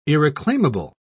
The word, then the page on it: irreclaimable: British English